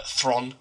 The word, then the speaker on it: Theron: Uh, Thron, sorry, that's how you actually pronounce her name, apparently.